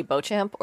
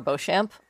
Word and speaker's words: Beauchamp: We actually just say Beauchamp or Beauchamp. – Beauchamp. Okay. – The southern way of saying it is the English way of saying it, which is Beecham. I told my husband years ago that the first Mrs. Beecham was going to be his second wife. So. – Right. – I have a double major in French. I'm not saying Beecham. – So Beauchamp is... – Yeah, Beauchamp or Beauchamp with a soft C-H.